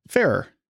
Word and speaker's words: Ferrer: Do you know how you pronounce it? – Ferrer. – Ferrer. That's what I thought.